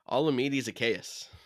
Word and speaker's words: Zaccheaus: Olamide Zaccheaus.